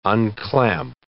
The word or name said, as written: unclamp